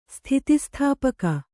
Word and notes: ಸ್ಥಿತಿಸ್ಥಾಪಕ: sthiti sthāpaka

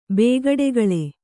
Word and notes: ಬೇಗಡೆಗಳೆ: bēgaḍegaḷe

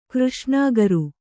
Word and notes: ಕೃಷ್ಣಾಗರು: křṣṇāgaru

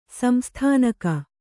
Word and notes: ಸಂಸ್ಥಾನಿಕ: samsthanika

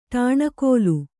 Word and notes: ಟಾಣಕೋಲು: ṭāṇakōlu; kannada

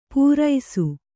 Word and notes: ಪೂರೈಸು: pūraisu

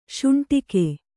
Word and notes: ಶೌಂಡಿಕ: śauṇḍika

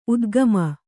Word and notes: ಉದ್ಗಮ: udgama

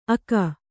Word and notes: ಅಕ: aka; kannada

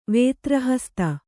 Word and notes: ವೇತ್ರಹಸ್ತ: Kannada

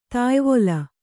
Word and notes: ತಾಯ್ವೊಲ: tāyvola

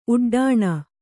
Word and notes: ಉಡ್ಡೀಣ: uḍḍīṇa

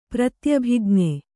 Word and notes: ಪ್ರತ್ಯಭಿಜ್ಞೆ: pratyabhijñe

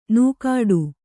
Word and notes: ನೂಕಾಡು: nūkāḍu

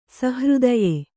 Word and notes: ಸುಹೃದಯ: kannada; suhřdaya

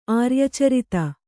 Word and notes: ಆರ್ಯಚರಿತ: kannada

